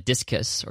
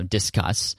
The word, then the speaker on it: Disqus: Then I switched over to Discus, as it's pronounced. I finally figured out what that actually meant. But D-I-S-Q-U-S, so Discus.